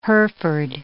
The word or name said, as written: Hereford